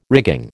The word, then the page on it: rigging: American English